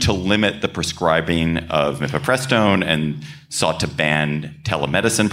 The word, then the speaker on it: mifepristone: It concerned, I'm going to say it, mifepristone. Did I say it correctly? – Excellent.